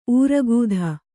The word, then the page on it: ಊರಗೂಧ: Kannada